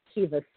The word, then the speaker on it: Caoimhe: And I know this because my daughter's name is Kiva, C-A-O-I-M-H-E.